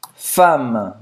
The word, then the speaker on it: femme: Minha mulher, ma femme. Ma femme. Presta atenção, não, não é com A, é com E, mas se pronuncia como se fosse um A ma femme, femme.